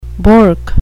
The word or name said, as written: bork